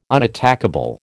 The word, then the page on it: unattackable: American English